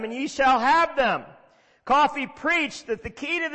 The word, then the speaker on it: Caughey: You spell it C-A-U-G-H-E-Y, but you say it Coffey.